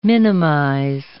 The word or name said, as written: minimize